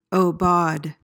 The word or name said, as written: aubade